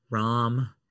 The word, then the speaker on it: Rahm: We discussed this 2 weeks ago, how to say his name, when he got COVID, remember? – Oh, right. Rahm. – Yes.